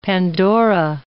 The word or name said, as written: pandora